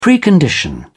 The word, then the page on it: precondition: British English